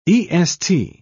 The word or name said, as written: est